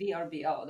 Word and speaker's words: Vrbo: VRBO, which now they changed the way you pronounce it to Vrbo, but back in the day, it was VRBO.